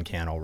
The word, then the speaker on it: Cannell: So, I mean, wherever you're hanging out, we're trying to bring you content natively there, whether it's TikTok, Facebook, Instagram, Sean Cannell, rhymes with YouTube channel.